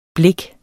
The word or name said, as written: blik